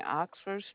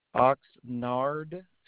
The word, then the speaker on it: Oxnard: You saying Oxford Street? – Oxnard Street and Vineland.